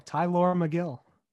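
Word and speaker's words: Megill: McGill, is that how you pronounce it? The pitcher? – Yeah, Tylor McGill.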